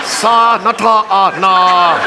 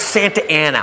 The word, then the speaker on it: Santa Ana: Sa-na-ta-na. How? It's Santa Ana. What says Santa Ana? Look, I know I didn't finish school, but that frickin' says Sa-na-ta-na. They pronounce it Santa Ana. Well, they're wrong!